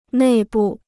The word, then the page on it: 内部: nèi bù